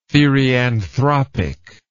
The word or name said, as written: therianthropic